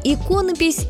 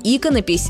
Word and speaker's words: иконопись: Слово «иконопись» многие, к сожалению, произносят неправильно. «Иконопись» или даже «иконопись». Согласно исследованию, проведенному одним из интернет-ресурсов, такие ошибки делают до половины опрошенных. Возможно, причина в том, что в однокоренном слове «икона» ударение падает на 2 слог, а в слове «иконописец» — на 4. Так что нужно просто запомнить «иконопись» с ударением на 1 слоге.